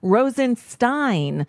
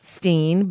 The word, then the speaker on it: Rosenstein: How difficult is it to get the pronunciation of the man's name right? Rosenstein, not Steen, but yeah, it's what? – Dr. Frankenstein.